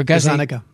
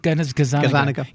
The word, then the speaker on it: Gazzaniga: So would you just mention the research of, I think his name is Michael Gunizaga, is that how it's pronounced? – Or Gazzaniga. – Gazzaniga.